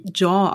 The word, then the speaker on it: Zhao: Äh, Regie hat geführt äh, Chloe Jean. Ich hoffe, ich spreche das richtig. – Ich glaube, Zhao. – Zhao? Nee, ich glaube, also sie, ich habe einen Artikel gelesen, dass es wie Jaw ausgesprochen wird.